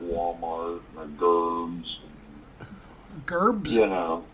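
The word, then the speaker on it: Gerbes: Gerbs.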